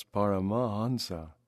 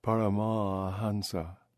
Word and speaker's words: Paramahansa: For with all those A's staring at them, they feel the need to linger on the middle one, as if gathering strength to move on to the finish. Paramahansa. An Indian, on the other hand, sails over that A as if it didn't exist. Thus, that is to say, most Indians don't pronounce it at all.